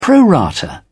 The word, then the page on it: pro rata: British English